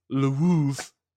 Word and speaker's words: Lwów: But the town Zawadzki was born in is called, it's spelled L-W-O-W, which is not pronounced Lwow, which it should be. It's Lwów. Lwów.